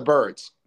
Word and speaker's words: Byrds: It begins with a B, and it's it's an animal, or it's not spelled like it, but it's the Byrds.